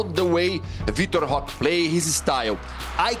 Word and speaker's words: Vitor Roque: Vitor Roque.